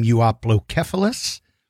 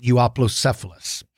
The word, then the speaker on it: Euoplocephalus: There are different pronunciations. Some of you might pronounce its name Euoplocephalus. Some Euoplocephalus. I pronounce it Euoplocephalus.